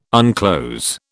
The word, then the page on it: unclose: British English